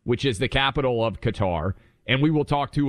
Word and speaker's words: Qatar: I mean, for the Arabic speakers out there, it's Qatar, but we're Americans, so we call things whatever we want.